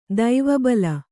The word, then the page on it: ದಿವಾಬಲಿ: divā bali